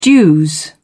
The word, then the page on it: dues: American English